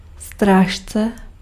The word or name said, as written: guard